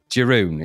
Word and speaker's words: Jeroen: Jeroen.